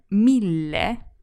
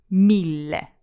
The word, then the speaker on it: mille: In mille, which is a thousand, there's a double L, which should be really nice and long. Mille. – Mille. Mille. – It also ends in the letter E, which is pronounced E.